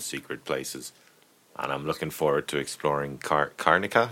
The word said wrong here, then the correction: Karnaca: Is that how you pronounce it? – Karnaca, I think. – Karnaca. – Yeah.